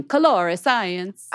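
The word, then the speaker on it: Colorescience: I know, but it's got that E in there. Yeah. It always throws me off. – That's weird. Oh, God. – And I want to say it like Italian. Colore Science. – I think I've been dropping the E.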